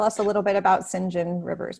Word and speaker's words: St. John Rivers: So his name, it's spelt St. John Rivers, but I believe we say St. John Rivers, so I'm going to say that.